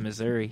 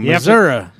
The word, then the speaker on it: Missouri: We can't do that in Missouri. You have to go stand in line. – You said it wrong. Missouri.